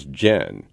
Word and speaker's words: John: It turns out that back in the 11th century, the Normans would have pronounced John as Jen.